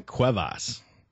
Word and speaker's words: Cuevas: Cuevas.